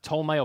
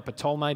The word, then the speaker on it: Ptolemy: Antiochus, Cassander, Ptolemy or Ptolemy, depending if you pronounce the P, and Seleucus.